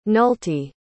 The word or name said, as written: nulty